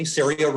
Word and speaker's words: CERIA: CERIA rhymes with area.